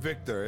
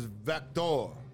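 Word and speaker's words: Viktor: It wasn't Victor, it was Vector. Yeah. Victor, you gotta say it like fucking Russian, bro. – It's not American, bro. It's Vector.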